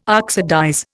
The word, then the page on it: oxidize: Indian